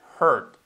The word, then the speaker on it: hurt: Cuidado que tenemos la H, que nunca sería hurt. ¿Ok? Y la R, que tampoco sería r, sería r. ¿Ok? Entonces sería hurt.